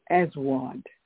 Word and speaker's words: Aswad: Black in Arabic is Aswad. A-S-W-A-D. Aswad. Black in Arabic is Aswad.